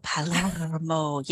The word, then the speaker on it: Palermo: I know, so you've Josephine got to roll the R. – Palermo. Oh, Palermo.